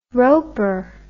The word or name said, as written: roper